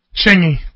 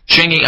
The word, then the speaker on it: Chingy: Chingy. – Chingy.